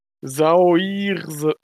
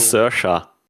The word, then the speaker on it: Saoirse: Sauirse. Ach, ich habe das gemacht. – So. Sersha. – Oh, das ist irgendwie. – Sersha Ronan.